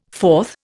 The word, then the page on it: fourth: Indian